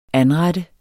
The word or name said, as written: anrette